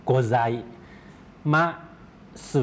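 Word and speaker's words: โก ไ ซม าส (ございます): โกไซมาสึออกเสียงรวบยาวมาสนะครับ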